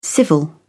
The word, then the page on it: civil: British English